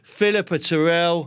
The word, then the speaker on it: Philippa Turrell: She's the editor of the Kitchen and Bathroom News, Philippa Turrell.